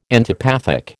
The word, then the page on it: antipathic: American English